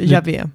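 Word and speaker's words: Jahve: Just det, Javé. Javé, mm. man tror det uttalades så.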